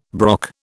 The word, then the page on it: brock: British English